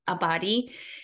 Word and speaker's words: Abbate: Abbate is an astrologer, because that was the thing, like before we got together, I was like, Ciera, what's your last name? – I know.